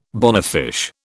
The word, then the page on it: bonefish: British English